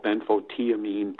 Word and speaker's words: benfotiamine: And benfothiamine, but the way it's spelled, I've been calling it benfoshamine, or some people call it benfotiamine, but it's B as in boy, E, N as in Nancy, F as in Frank, O, T as in Tom, IAMINE, benfothiamine or thiamine.